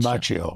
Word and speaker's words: Macchio: Macchio.